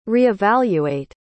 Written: reevaluate

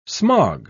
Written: smog

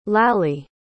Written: Lally

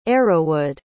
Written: arrowwood

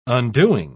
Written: undoing

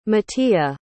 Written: Metea

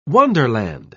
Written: wonderland

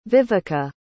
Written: viveca

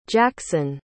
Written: jaxon

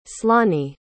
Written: slany

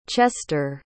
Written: chester

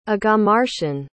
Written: aghamarshan

